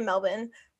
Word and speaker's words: Melbourne: The Australians say Melbourne, but because it's like their accents, Melbourne.